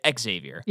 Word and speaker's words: Xavier: Xavier.